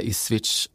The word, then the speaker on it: Ipswich: Man ska säga Leicester, man ska säga Ipswich,